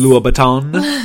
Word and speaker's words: Louboutin: Louboutin. That's how it's pronounced.